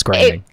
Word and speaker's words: Groening: I can assure you it's pronounced Groening.